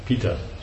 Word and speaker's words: PETA: Is that how you say it? Peta or Pita? – Pita.